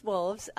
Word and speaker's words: Wolfes: So Run with Wolves, and of course it's spelled W-O-L-F-E-S because that's our last name, right?